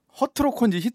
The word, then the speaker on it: 허트로커: 히트로커라는 작품 기억하실 텐데요. – 네, 네, 네. – 허트로커인지 갑자기 발음이죠.